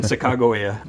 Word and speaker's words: Sacagawea: And Sacagawea, which is the pronunciation, you know, more preferred by, you know, the descendants of her people, she gets spelled many different ways within it.